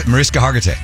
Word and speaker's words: Mariska Hargitay: Mariska Hargitay. Oh, I can never say her name. – Mariska Hargitay.